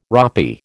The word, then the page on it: ropy: American English